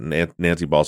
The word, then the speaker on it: Baugh: Is it Bo or Ba? How do you pronounce her last name? – Ba. – Yeah, Nancy Baugh.